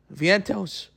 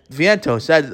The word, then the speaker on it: Vientos: If the Met offense continues to struggle, call up Viento. Vientos. Sorry if I butchered his name. Vientos, that's the right pronunciation.